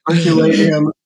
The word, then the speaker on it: Herculaneum: Tell us about Herculean how do I say it? Papyri. – Herculaneum papyri. Yeah.